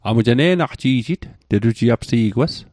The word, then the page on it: ne'naqji'jit: Mi'gmaq